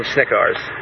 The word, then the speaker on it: Snickars: Snickars. – How do you spell that? – It's like the candy bar, only ARS.